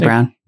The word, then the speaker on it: Brené Brown: What with, is it Brené Brown? Am I pronouncing Brené Brown, yeah.